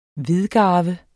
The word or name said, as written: hvidgarve